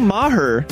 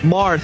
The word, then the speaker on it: Maher: Basically, it's phonetics, dude. Sounded out. Maher is not it. Mar. – Marcude making Mar.